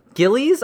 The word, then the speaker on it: Gillies: This is Daniel Gillies, I want to say his name is. It's G-I-L-L-I-E-S.